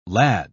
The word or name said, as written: lad